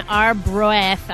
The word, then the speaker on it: Arbroath: It's called the Elms, and it's in Arbroath.